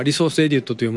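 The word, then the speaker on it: ResEdit: レスエディットはアイコンを書き換えたり、ちょっとしたカスタマイズをコワゴワ施してたなぁ、といただいてまして、レスエディットと読むのか、リソースエディットと読むのか、人によってちょっと違って、私はレスエディットと呼んでますが、これがスミレさんは全然知らなかったアプリ。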